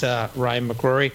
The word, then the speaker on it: Ryan McGrory: Ryan McGrory, who's the Employee Engagement Team Manager at, and he's probably going to correct me, but I have it as UE Insurance.